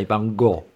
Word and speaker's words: Van Gogh: And the guy went, some people say Van Gogh, some people say Van Gogh, but we say Van Gogh.